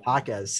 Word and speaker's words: Jaquez: It's Jaquez, right? – I think it's Jaime Jaquez. – Jaquez. – Yeah. Jaquez. Silent J always gets me, but yeah.